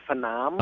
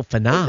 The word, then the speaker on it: Phenom: It's called, it's pronounced Phenom. – Oh, Phenom. – And phenomenal, but you just pronounce the first part of it, Phenom.